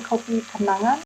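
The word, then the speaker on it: Kopi Kenangan: So in Indonesia, we say Kopi Kanangan, where Kanangan means something like a memory.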